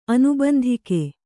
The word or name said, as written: ಅನುಬಂಧಿಕೆ